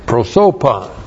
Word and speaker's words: prosopon: And then we have prosopon. Looks like that in the Greek, P-R-O-S, long O, P-O-N, prosopon. Prosopon. Literally, it means face or a person.